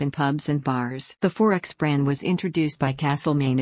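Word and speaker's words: XXXX: 4X, pronounced 4X,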